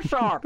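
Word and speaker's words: Dvořák: Not Dvorak. – That's right. – Dvorak. – That's right. – That's how you say it.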